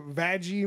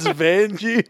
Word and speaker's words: Vangie: Have a conversation about like what did Vanessa Vaggie Mateo wear? I don't know. – It's not Vaggie. It's Vangie. – Whatever. – Vangie sounds really aggressive. – That's how it's spelled. – It's Vangie, not Vangie.